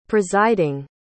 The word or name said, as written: presiding